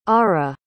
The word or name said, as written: Arah